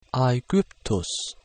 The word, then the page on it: Aegyptus: Latin